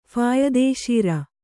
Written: ಫಾಯದೇಶೀರ

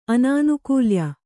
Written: ಆನನುಕೂಲ್ಯ

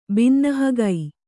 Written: ಬಿನ್ನಹಗೈ

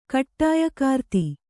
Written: ಕಟ್ಟಾಯಕಾರ್ತಿ

